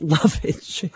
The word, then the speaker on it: lovage: And secondly, what is lovage? – Well, you are pronouncing it correctly. That's how I say it, lovage.